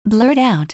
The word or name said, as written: blurt out